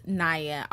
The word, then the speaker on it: Nia Jax: was someone who got really nervous um, during this time, or she was expressing that she was really nervous, was Naya. I always say it wrong. I always say Nia, but you know, it's you know who I'm talking mm. about. Nia Jax. So if I throw that in there, sorry guys.